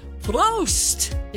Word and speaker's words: Prost: Well, if you want to do it correct, you'll raise the glass and say, Prost! Yeah, Oktoberfest kicking off in Obergatlinburg, Bavarian style. – Please spell it. – P-R-O-S-T.